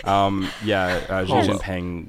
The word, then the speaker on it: Xi Jinping: So there was a lot of memes going around where, Xi Jinping was like being...